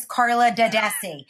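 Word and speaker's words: Carla D'Addesi: And secondly, for saying my name correctly. It is Carla Dadesi. Now, are you doing your hand motion like a good Italian? You know, you get the hand motion going with the Carla Dadesi. That's how you say it in Italian.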